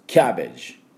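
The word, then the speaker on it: cabbage: チッチッでチッチッチッに変えていただければ、キャベツの音はキャベジで、キャ、ここにアクセントがあります。キャベジ、キャベジということになります。キャベのところは BA のところは半母音です。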